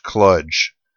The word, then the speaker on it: kludge: Judge Steve Silverman has ruled that the correct pronunciation, at least for our purposes, is kludge. So kludge is in fudge, folks.